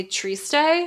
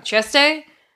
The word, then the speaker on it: Trieste: So if you go from Torino to Milan, to Brescia, to Trento, to Padua, to Venice, and then finally Trieste, I always say that wrong, Trieste, that's the trail of spritz.